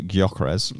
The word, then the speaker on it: Gyökeres: Uh, and a word for Viktor Gyökeres. – Yeah, well pronounced.